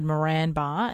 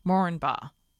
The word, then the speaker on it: Moranbah: I said Moranbah, and he said the accepted way to pronounce the name is Moranbah. The first A is not stressed.